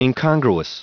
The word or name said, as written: incongruous